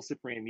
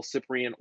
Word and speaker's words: Cyprian: So Instagram, you can find me at Will Cyprian Music. And the same thing for Facebook. Facebook, you can find me Will Cyprian or Will Cyprian Music. That last name is spelled C-Y-P-R-I-A-N.